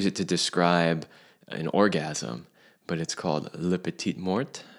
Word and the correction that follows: la petite mort: And I know I'm not a French speaker, so I probably mangled that. Céline, you can pronounce it properly for the audience. – La petite mort.